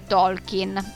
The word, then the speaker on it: Tolkien: Lo stesso dicasi ad esempio per Tolkien, la cui pronuncia corretta in realtà sarebbe Tolkien, ma non mi garba molto e mi sentirei una scema a dire Tolkien nel corso di questa sera.